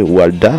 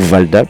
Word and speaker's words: Walda: Et bah, on, peut retrouver tout simplement, on peut la découvrir ensemble, Lotte Walda. Alors Lotte, ça s'écrit L-O-2-T-E, et plus loin Walda, donc son nom de famille, ou Valda plutôt, W-A-L-D-A.